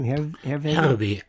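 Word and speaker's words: Herreweghe: how do you pronounce his last name? – Herbig. – Okay, I'll let you pronounce that for me. – No, Herbig is Dutch or Belgian.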